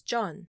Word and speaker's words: Jeon: Now let's talk about the character of Alex John. And in case it's hard to understand my pronunciation of Korean family names, John is spelled J-E-O-N.